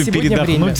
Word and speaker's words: передохнуть: В слове передохнуть, время. Главное верно сделать ударение. – Да, не надо вот на «о».